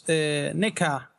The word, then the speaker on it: NECA: NECA announced the, I'm saying that right. No, you did it very well.